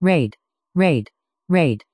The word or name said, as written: raid